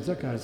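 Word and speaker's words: Zakar (זכר): Ma Zakar in ebraico, con una vocale che cambia letteralmente, ma si legge Zakar, significa maschio.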